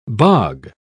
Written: bog

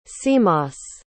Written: cmos

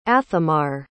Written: athemar

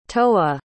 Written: toa

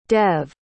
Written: dev

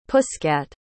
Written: pusscat

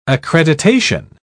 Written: accreditation

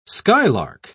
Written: skylark

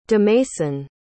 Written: demason

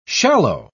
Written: shallow

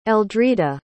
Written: eldrida

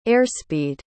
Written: airspeed

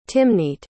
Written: Timneet

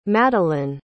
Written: Madalynn

